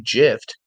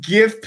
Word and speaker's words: GIF: I agree with you that it's pronounced GIF, but Steve Wilhite, who invented the GIF file in 1987, told New York Times that the word is pronounced not GIF. The Oxford English Dictionary accepts both pronunciations. They are wrong.